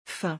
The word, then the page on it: fin: French